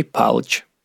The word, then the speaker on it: Палыч: А вот числе ударение на первом слоге, а все остальное глотается. Тимофей Палыч.